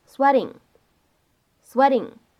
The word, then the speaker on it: sweating: 所以呢，在美式发音当中，这个单词呢，我们读成 sweating，sweating。